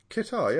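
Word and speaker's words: catarrh: Kitar, yeah. – Is that what you call it? Kitar? – Kitar, that's what it's called, isn't it? – It's a rhyme, as it rhymes with guitar.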